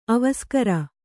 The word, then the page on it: ಅವಸ್ಕರ: avaskara